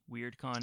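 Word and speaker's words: WyrdCon: There's a convention called WeirdCon, but it's spelled W-Y-R-D, WeirdCon.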